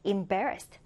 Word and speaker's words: embarrassed: 두 번째 음절의 강세를 두고 이때 발음 주의해 주세요. 맨 앞에 이 음절이 M 아니고 임이라고 발음이 됩니다. 그래서 embarrassed. embarrassed.